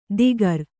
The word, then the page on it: ದಿಗರ: digara